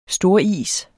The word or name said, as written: storis